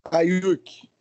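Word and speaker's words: Aiyuk: Qual é a pronúncia dele? Ayuk. Brandon Ayuk. Ayuk.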